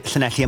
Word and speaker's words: Llanelli: and I think that's Llanelli and the Pont Abraham roundabout. Did I say that right? – You did. Llanelli and Pont Abraham, not Abraham.